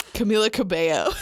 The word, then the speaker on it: Camila Cabello: Camila Cabello.